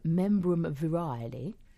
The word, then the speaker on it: membrum virile: Member comes from the Latin term membrum virile. I said that right. – I think you did.